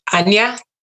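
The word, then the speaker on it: Ajna: And I spend a lot of time with my brow chakra, or Anya, as they pronounce it in Sanskrit, because that's where your intuition comes from, your knowledge, your clarity, your ability to think for yourself.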